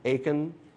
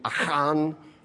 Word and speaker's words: Achan: Now, that's the anglicized pronunciation, Achan. That's the way I'm going to pronounce it tonight, like, oh, my Achan back. But it is a Middle Eastern name. It would be Achan. There'd be an aspirate in there, so it'd be Achan.